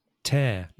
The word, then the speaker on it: tear: So in this case, we're pronouncing T-E-A-R as tear, which I think in Spanish is rasgar. It's like to break a piece of paper, for example.